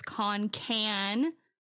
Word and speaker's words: Concan: Uh, it was about my Garner State Park episode that I did recently, and I pronounced the town Concan, and apparently it's Concan. Okay. Like like a yeah. can of soup.